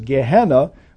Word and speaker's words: Gehenna: Another one is Gehenna, which we pronounce exactly the way the Greek is, Gehenna, both of which point to or try to convey a life of separation eternally from God, where God does not dwell.